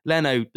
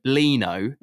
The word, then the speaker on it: Leno: So he calls Leno Lino. I'm like, how have you interpreted Leno is Lino, and he called Vinicius, he calls him Vinicus.